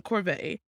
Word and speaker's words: Corbett: But of course, that's when she meets Ian, played by John Corbett.